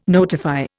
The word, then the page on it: notify: Indian